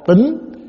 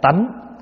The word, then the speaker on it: tính: Một là tính. Tính này cũng có thể đọc là tánh.